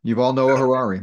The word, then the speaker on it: Yuval Noah Harari: So help me if I'm mispronouncing his name, Yuval Harari. – Yeah, Yuval Noah Harari.